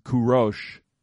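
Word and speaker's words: Kurosh (کوروش): The Hebrew pronunciation is Koresh. My Iranian friend Donya wrote the Persian script for Cyrus' name in my Bible here at this verse at 44:28, and told me to pronounce it as Kurosh.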